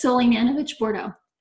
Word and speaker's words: Sulejmanovic-Bordeaux: It's Sulejmanovic-Bordeaux.